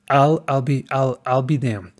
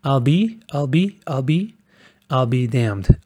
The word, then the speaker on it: I'll be damned: Pero aunque se escribe, I'll be damned, escuchen los sonidos. Es I'll, no es I will, y tampoco es I'll, es I'll be damned. I'll be damned.